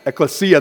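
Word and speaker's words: Ecclesia (ἐκκλησία): It's the word ecclesi. Yes, that word. I have a hard time pronouncing them. Ecclesia, there you go.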